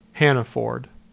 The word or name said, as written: Hanaford